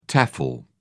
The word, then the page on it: tefl: British English